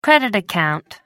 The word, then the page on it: credit account: American English